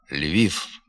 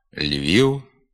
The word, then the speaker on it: Львів: Вимова «пішов», «сказав», «львів», «навпаки», «вперше» є ненормативною. Треба говорити «сказав», «львів», «навпаки», «вперше».